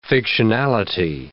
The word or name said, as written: fictionality